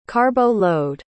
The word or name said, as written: carbo-load